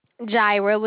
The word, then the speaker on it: Jaira: But before we go any further, I'm sorry, did you say your name is Syra? – Jaira with a J.